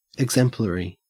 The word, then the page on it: exemplary: Australian English